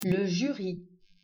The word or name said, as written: Jury